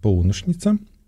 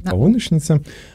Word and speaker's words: Полуношница: В некотором смысле я читаю ваш роман, а напоминаю, что сегодня мы будем говорить про дебютный роман Нади, это «Полуношница», с таким ударением. Я же правильно, да? – Все верно. – Да, «Полуношница».